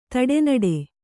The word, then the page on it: ದಿಟ್ಟಿನಡು: Kannada